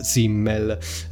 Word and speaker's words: Simmel: Questo filosofo di cui parleremo oggi è Georg Simmel,